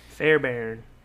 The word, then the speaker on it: Fairbairn: Fairbairn. – Fairbairn.